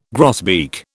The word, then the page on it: grosbeak: British English